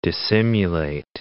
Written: dissimilate

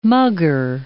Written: mugger

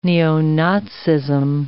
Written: neo-Nazism

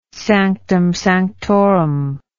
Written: sanctum sanctorum

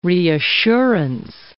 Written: reassurance

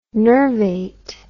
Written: nervate

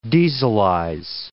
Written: dieselize

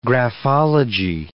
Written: graphology

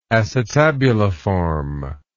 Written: acetabuliform

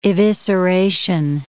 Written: evisceration

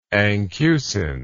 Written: anchusin

